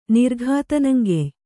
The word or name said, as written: ನಿರ್ಘಾತನಂಗೆಯ್